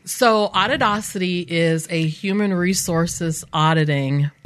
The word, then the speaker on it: Auditocity: Do you kind of laugh when people try to pronounce it? Like, Audio City. – Audio City. Yeah. Audacity. That's a good one. – Say it again. – It's Auditocity.